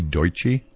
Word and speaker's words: Deutsche: And did you say Deutsche? – Deutsche, correct. – How do you spell it? – D-E-U-T-S-C-H-E.